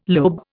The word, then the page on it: lobe: Indian